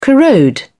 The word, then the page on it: corrode: British English